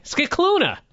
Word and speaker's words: Scicluna: Scicluna, you say? – Scicluna. – Right. – That's how you pronounce it.